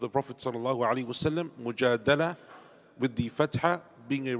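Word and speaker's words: Mujadala (مجادلة): And it is pronounced Mujadala with a fatha on the dal, and mujadila with a kasra on the dal.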